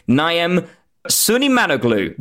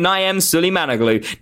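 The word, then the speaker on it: Naim Süleymanoğlu: I believe you pronounce it Naim Sulimanoglu. That's how you pronounce his name. Naim Sulimanoglu.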